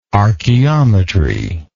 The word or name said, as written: archeometry